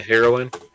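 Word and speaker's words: heroine: The heroin? – Heroin. I want to say... It sounds too much like the drug. – It is. It's the same.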